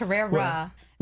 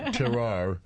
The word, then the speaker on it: terroir: The thing, and I don't know anything about the science, but they always talk, you know, I always hear about the terroir, and mm-hmm. I can't even say it right. Did I say it? – Terroir. – Terroir. – Yeah, it's terrera. – Well no, terroir.